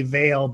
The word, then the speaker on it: Veal: It's like my last name is actually Vail, but it's spelt Veal.